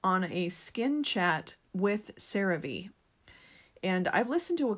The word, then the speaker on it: CeraVe: And to be honest, I realized it's pronounced CeraVe instead of CeraVe. I don't know. I think I was trying to be too fancy, but it is pronounced CeraVe.